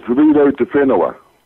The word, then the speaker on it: Riro te Whenua: It's Riro te Whenua. – Thank you. – So you have to roll the R's.